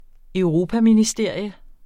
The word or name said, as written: europaministerium